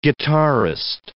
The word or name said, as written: guitarist